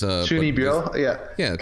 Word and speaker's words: Chunibyo: So it's like his interactions with Chinchinbuyo. How do you say that? Yeah, the Chunin. It's Chunibyo.